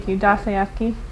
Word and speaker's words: Dostoevsky: In that novel, I think it's just, I don't know how to pronounce his last name, it's Dostoevsky or something like that. – Dostoevsky.